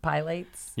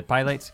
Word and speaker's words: Pilates: And I think it's pronounced Pilates. – Yeah, Pilates.